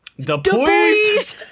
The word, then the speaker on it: Dupuis: It's D-U-P-U-I-S. Cool. How do you pronounce that? Dupuis? – Dupuis? – Dupuis? – Dupuis. – Dupuis? Dupuis. It's Dupuis! Amanda Dupuis!